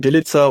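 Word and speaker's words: Bjelica: Wie spricht man den Nachnamen richtig aus? Bilica? – Wir nennen ihn Bilica und ernten damit zumindest keinen Widerspruch bisher. Also Bilica ist das, wie er angesprochen wird von uns.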